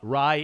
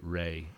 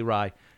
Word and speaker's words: Ray: Ray in the original Bengali pronunciation more commonly comes to us through Hindi sources as Ray. Satyajit Ray is what most people say, but turns out it's actually Ray, and I've corrected myself on that this week, so I'm going to stick with it.